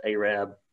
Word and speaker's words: Arab: I graduated from Arab High School, pronounced Arab, spelled Arab.